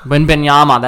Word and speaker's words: Wembanyama: Wembanyama. He's French, so another European player. This is why I bring this up. – Victor Wembanyama. – Wembanyama, that's it. That's how you say it.